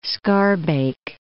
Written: Schaarbeek

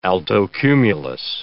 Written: altocumulus

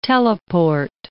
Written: teleport

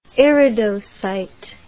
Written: iridocyte

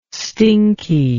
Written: stinky

